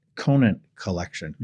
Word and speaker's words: Conant Collection: Tell me about Conant Collection.